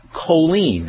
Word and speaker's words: choline: choline. That's spelled C-H-O-L-I-N-E, choline.